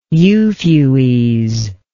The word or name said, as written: Euphues